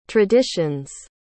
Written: traditions

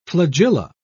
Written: flagella

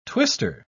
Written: twister

